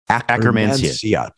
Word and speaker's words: Akkermansia: Akkermansia. – Well, I was just leaning into it. Thank you very much. Let's do it together. 3, 2, 1. Akkermansia.